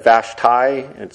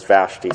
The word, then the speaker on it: Vashti: By the way, I've looked up the pronunciation of that name, and I think last time I said Vashti, and that is what some say is the pronunciation, but then others say it's Vashti. Well, I think I grew up learning as Vashti, so I'm going to say Vashti in this sermon.